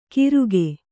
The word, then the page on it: ಕಿರುಗೆ: Kannada